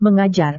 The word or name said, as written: mengajar